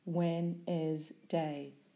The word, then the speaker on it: Wednesday: S-N-A-L. Syllables. Snail. Again, phonemes. A-G-A-N. Syllables. Again. Away, phonemes, a, w, a, syllables, away. Wednesday, phonemes, w, e, n, e, z, d, a, syllables, when is day. And in this word, the letters dn are making an n sound.